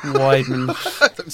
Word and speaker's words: Weidman: And Wildman is the one who kind of... – Wildman or Wideman? – Wideman. There's an awful pronunciation error in my lifestyle.